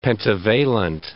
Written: pentavalent

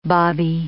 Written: bobby

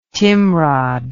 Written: timrod

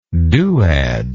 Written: duad

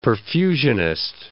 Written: perfusionist

